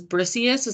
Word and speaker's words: Briseis: Her name was Briseis. Is that how you say it? – Yeah.